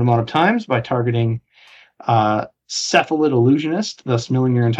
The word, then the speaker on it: Cephalid: It is included in a popular archetype called Silaphid Breakfast. – It's called Cephalid, by the way. – Huh? – It's Cephalid is how it's pronounced.